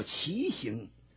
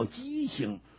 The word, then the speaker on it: 畸形: 今天的人生不正常啊，医学上讲叫畸形啊，畸形，有人的把那字念白了叫畸形。实际上那个发音，正确的发音叫畸形。